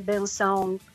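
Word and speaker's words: Bênção: che bella pronuncia no no ma non è che sia facile io ci ho messo qualche anno prima di dire Benson eh, con eh, la nasale un po' corretta, ma comunque se io mm.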